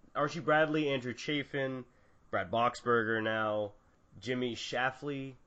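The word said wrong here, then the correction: Sherfy: I completely butchered that. Jimmy Scherfie, a couple others, but yeah, that sounds so much better.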